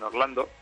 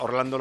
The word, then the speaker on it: Orlando: Y es que Atlanta, Orlando lo pronuncian Alana Olano.